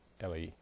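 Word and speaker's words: Eli: And Ellie, I would pronounce it, it's E-L-I, but she pronounces it Ellie. Ellie.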